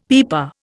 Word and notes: pipa: Indian

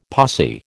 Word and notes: posse: American English

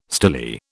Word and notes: stilly: British English

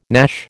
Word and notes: gnash: American English

